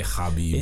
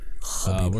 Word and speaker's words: Khabib: Khabib. Is it Khabib or Khabib? – I think it's like Khabib. It's definitely Khabib.